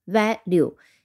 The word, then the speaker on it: value: 嗯，它的读音其实蛮简单的，分成两个音节，value，v a，a 读成短音，a，l u e。 u e 读的发音就是和 u 这个字母的发音是一样的 u，那么你可以当 e 是不发音的，发成 u 本身的读音，加上 l 这个辅音 value v a l u e 这个词它的基本意思就是 how much something is worth in money or other goods 啊，它的价值值多少钱。